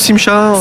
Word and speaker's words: Simcha: Il y a une prononciation, c'est juste Simcha euh, Simcha, C'est aussi un prénom.